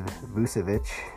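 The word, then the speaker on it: Vučević: I may have said his name wrong back there. But it is Vucevic.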